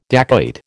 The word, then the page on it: dacoit: American English